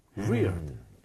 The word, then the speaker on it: weird: 아, 근데 계속 틀리는 것도 이상한데? 이상해. – 아, 그래요? – 이거는 wired죠. – 그러니까 wired인데 너무 이상하니까 얘기하는 거야? wired는 뭔가 연결된 느낌 아닌가요? 그쵸? 와이어가 이렇게. – weird.